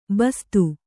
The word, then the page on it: ಬಸ್ತಿ: basti